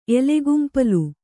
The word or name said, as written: ಎಲೆಗುಂಪಲು